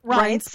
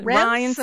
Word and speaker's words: Reince: Reince Priebus. – Why people still call it Rance.